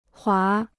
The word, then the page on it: 滑: huá